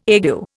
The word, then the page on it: ague: Indian